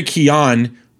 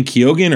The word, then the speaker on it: Keoghan: Barry Keon, everyone's been saying Keoghan or Keegan. It's Keon because he's Irish. His last name's Keon.